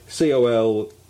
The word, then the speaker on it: Colquhoun: Quite funny, she's called Jay Aston Colquhoun now, which is obviously Colquhoun, as it pronounced, as it sounds.